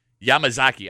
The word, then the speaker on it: Yamazaki: Yamakaze. – Yeah. – Yamazaki. I always say it wrong.